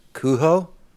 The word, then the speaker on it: Cujo: Also, weird fact for the day, apparently Cujo is actually pronounced Cujo. It's supposed to have like a Mexican connotation to it.